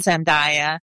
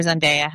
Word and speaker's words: Zendaya: I was really impressed with him and Zendaya, or Zendaya, however you say her name.